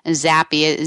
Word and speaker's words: Zappia: And also, I usually don't spell my name during the show, but for those people that don't know that Chella is actually C-E-L-L-A, Zappia, Z like zebra, A-P-P-I-A, and Chella, that comes from an Italian pronunciation.